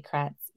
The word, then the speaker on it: Kratz: And I'm very excited to have Julie Kratz here of the Next Pivot Point.